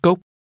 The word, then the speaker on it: cốc: Hai cốc. Cốc. Cốc. Hai cốc. Hai cốc. – Glasses is pronounced with a rising tone.